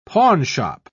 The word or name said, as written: pawnshop